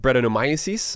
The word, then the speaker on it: Brettanomyces: And this one was fermented with Belgian yeast and Brettanomyces. Uh, what were your thoughts on this beer? – Oh, nice pronunciation on Brettanomyces there.